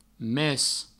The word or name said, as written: mes